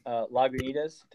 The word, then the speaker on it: Lagunitas: This was so other drain pours like Lagunitas. Did I say that right? – You did.